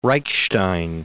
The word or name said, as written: Reichstein